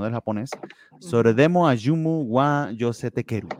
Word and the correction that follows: Yosetekuru: Yosete Kuru.